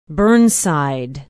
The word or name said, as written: burnside